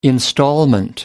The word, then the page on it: instalment: American English